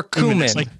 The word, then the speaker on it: cumin: Is it cumin or cumin? – I always say like cumin. – Rhymes with human? – Rhymes with human, yeah.